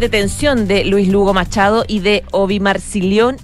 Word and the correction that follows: Marlicción: no, ya lo dije mal, Obi Marlicción.